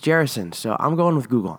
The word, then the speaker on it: Gerasenes: So Mark says that Jesus and his disciples crossed the Sea of Galilee and landed in a place called Gerasenes. And that is how you say the name of the place, actually, because I looked it up on Google, and because I wasn't 100% sure how to say it.